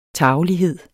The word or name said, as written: tarvelighed